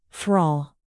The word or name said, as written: thrall